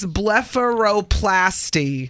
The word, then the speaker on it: blepharoplasty: Blepharoplasty. Is that right? – That's correct. – Did I do that right? – Right. Blepharoplasty.